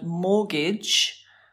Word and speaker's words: mortgage: Number 14 is another word origin coming from the French, the word mortgage. We know this word. – Because it's not pronounced mortgage. – Exactly. It's spelt with a T, but the T is silent.